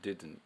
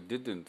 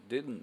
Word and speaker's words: didn't: didn't。で多くの人はこれ didn't って言ってるんですけどね。でこれ普通に楽に発音すると、多くのネイティブスピーカーの人たちは didn't っていうふうに発音するんですよね、 didn't みたいな感じで didn't、ゆっくりやるとこんな感じです。途中まで D なんだけど、あの途中から N に知らん間に変わります。